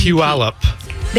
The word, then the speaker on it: Puyallup: P-U-P-U-Y-A-L-L-U-P. – Puyallup.